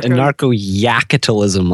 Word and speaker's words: Anarcho-Yakitalism: How do you say it? It's Anarcho Yakitalism. – Anarcho Yakitalism, like capitalism, but with yak instead of cap.